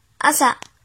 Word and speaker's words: 朝: 音读的时候读作“ 秋秋秋”，比如早餐“ 朝食朝食朝食”，写成日语汉字是“ 朝” 加食欲的“ 食”。训读的时候读作阿萨阿萨阿萨，比如朝阳阿萨希阿萨希阿萨希，写成日语汉字是朝加日。